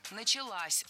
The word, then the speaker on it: началась: Началась. Похожая ошибка, примерно такая же, что и в предыдущем примере, но у нас здесь женский род. Опять же, возвратная часть «с» притягивает ударение к себе. Началась. Ударяем последний слог.